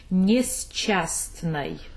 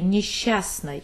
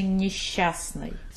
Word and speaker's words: несчастной: So this word I would pronounce, if I pronounce exactly how it's written, I would say несчастной. – Mm-hmm. – But then we say несчастной, and this T we don't pronounce as well. – After the second C. – After the second C, we don't pronounce that, even when we, sometimes, of course, you can do that, but normally, no. Normally, you say несчастной. So it pronounces like sh.